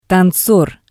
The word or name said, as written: tanzor